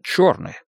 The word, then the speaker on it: Черны: Спасибо, что пришли, мистер Черны. Черны. – Правильно, черный. Пишется «е», а читается «о».